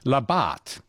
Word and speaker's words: Labatt: this place near Streaky Bay, it's Labatt, okay, Point Labatt, with a French accent. Because Baudin named it.